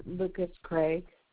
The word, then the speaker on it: Lucas: It's going to be under Lucas Craig. – Okay, one moment. You spell that L-U? – L-U-C-A-S.